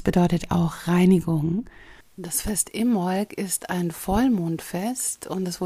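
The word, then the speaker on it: Imbolc: Also das erste Jahreskreisfest im Jahr heißt Im Molk, geschrieben mit B, Im Molk. Aber richtig ausgesprochen, wie ich gelernt habe, als ich in Südengland war letztes Jahr, ohne B, also im Molk.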